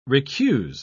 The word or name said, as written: recuse